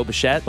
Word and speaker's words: Bichette: Tell me if I'm saying it right, Bo Bichette. – Okay. Yeah. – Didn't know if it was Bichette.